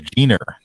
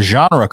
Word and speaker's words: genre: I didn't want this to get too far away, but I have a kid in my class said pronounce genre as Gener, G-E-N-E-R. – Gener. – And this is the punchline. It's a genre class.